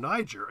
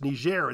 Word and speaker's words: Niger: Niger. And he got roundly criticized by the receptionist at the embassy, who said it should be pronounced Niger. It's my country. We pronounce it Niger.